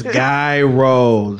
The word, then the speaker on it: gyro: It's gyro. – It's not jai? – No, guy. – You know what? My wife is probably, she gonna correct me. – You're right. – It's gyro.